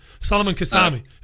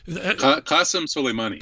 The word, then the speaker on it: Qasem Soleimani: Soleimani. – Qasem Soleimani.